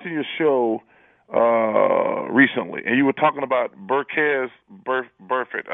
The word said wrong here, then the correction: Vontaze Burfict: I think. Did I pronounce his name? – Vontaze Burfict. – Vontaze, yeah.